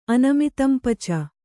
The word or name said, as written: ಅನಮಿತಂಪಚ